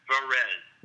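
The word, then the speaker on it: Varèse: It's actually Varese. It's like, I don't know if you're familiar with the classical composer Edgar Varese, but it's named after Edgar Varese. It's Varese.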